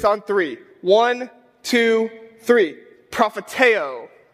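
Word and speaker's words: prophetēo (προφητεύω): In the Greek, this word is pronounced prophetēo, all right?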